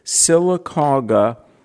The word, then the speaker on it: Sylacauga: if I'm pronouncing this correctly, Sylacauga, Alabama. – You got it. That's how you say it. Even in Amen. – Alabama, you did good.